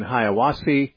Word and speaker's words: Hiawassee: And our first caller today is from Mark in Hiawassee, Georgia.